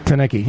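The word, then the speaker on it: Teneycke: But I know Tenecki, if I'm pronouncing his name correctly, I think I am. – Tenecki, yeah.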